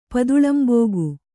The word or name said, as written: ಪದುಳಂಬೋಗು